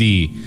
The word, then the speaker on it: Yendi: No Melendi ni nada que me estaban diciendo. Yendi. Se escribe Y-E-N-D-D-D-D-D-D-D-D-D-D-D-D-D-D-D-D-D-D-D-D-D-D-D-D-D-D-D-D-D-D-D-D-D-D-D-D-D-D-D-D-D-D-D-D-D-D-D-D-D-D-D-D-D-D-D-D-D-D-D-D-D-D-D-D-D-D-D-D-D-D-D-D-D-D-D-D-D-D-D-D-D-D-D-D-D-D-D-D-D-D-D-D-D-D-D-D-D-D-D-D-D-D-D-D-D-D-D-D-D-D-D-D-D-D-D-D-D-D-D-D-D-D-D-D-D-D-D-D-D-D-D-D-D-D-D-D-D-D-D-D-D-D-D-D-D-D-D-D-D-D-D-D-D-D-D-D-D-D-D-D-D-D-D-D-D-D-D-D-D-D-D-D-D-D-D-D-D-D-D-D-D-D-D-D-D-D-D-D-D hasta ahí nomás llegamos.